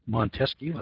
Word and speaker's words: Montesquieu: Federer, Sir Francis Bacon, Hugo Grotius, John Locke, Sir William Blackstone, the philosophers like Montesquieu, is it Montesquieu, I think is the way you pronounce it,